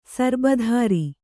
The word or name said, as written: ಸರಿಬಿತ್ತರಿ